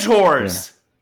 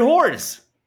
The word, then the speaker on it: horror: Dude, the revenge horrors. – Revenge. Oh, no. The revenge horror? – Yeah, that's what I'm saying. – Oh, yeah, God. Horror. Not horrors. It's like two syllables.